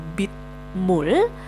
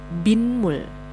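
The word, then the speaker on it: binmul (빗물): Ini bukan menjadi bimul, tapi karena bi-nya diakhiri huruf vokal dan kita bisa tambahkan dengan huruf saisiot setelah bi-nya, maka pelafalannya bukan dipisah menjadi bitmul, nah, melainkan akan menjadi binmul. Binmul ya.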